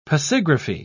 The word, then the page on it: pasigraphy: British English